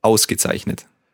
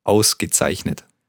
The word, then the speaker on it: Ausgezeichnet: Let's take it syllable by syllable. The first word, the first syllable? – Aus. – Aus. – Ge. – Ge. – Zeichnet. – Zeichnet. Ausgezeichnet. – Yeah, very good.